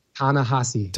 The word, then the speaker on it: Ta-Nehisi: Ta-Nehisi.